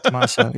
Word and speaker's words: Tommaso: Is that how you say it? Tommaso? – Yeah. Tommaso? Yeah. Tommaso. Yeah. – Yeah. Like that.